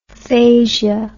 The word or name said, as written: phasia